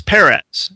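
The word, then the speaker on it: Pérez: But this letters page told me something that I didn't know up to that point, and that is the pronunciation of George Perez, accent on the first E. They actually had that in a little yellow thing by the editor. Instead of Perez, it's Perez.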